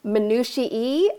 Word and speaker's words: minutiae: Have you ever heard it pronounced minutiae? And I was like, there is no possible way that's a real word. There's no way. It turns out that is how it's pronounced.